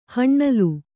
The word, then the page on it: ಹಣ್ಣಲು: haṇṇalu